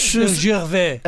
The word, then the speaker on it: Gervais: Mas antes temos Ricky Gervais, é assim? Tu que és o especialista das pronúncias? – Gervais, uh, olha, acho, acho que está bem. – Ger Gervais. Gervais. Gervais. É, Gervais. Pelo menos é assim que ele é? Gervais.